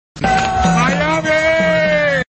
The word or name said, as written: Miami